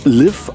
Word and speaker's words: Liv: Hier ist Liv E, eigentlich pronounced Liv, aber dann wisst ihr vorm Radio wieder nicht, was los ist.